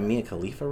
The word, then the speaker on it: Mia Khalifa: Like that Mia Khalifa reference? – Okay.